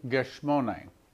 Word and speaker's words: Gethsemane: Then he went to the Garden of Gethsemane, which is the way you pronounce it in Hebrew, which actually means oil press.